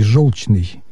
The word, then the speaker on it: желчный: Почему основным является вариант с буквой «ё»? По той простой причине, что слова «желчный», «желчь» и «желтый» однокоренные.